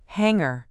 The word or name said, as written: hangar